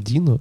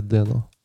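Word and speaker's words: Deno: Не знаю, это, это считается как Дино или Дено? По-моему, Дино, да? – Дино. – Ну, я слышал, что Дена больше. – Это вообще Дена, да, но, но как-то, по-моему, в русском Дина называют его, нет?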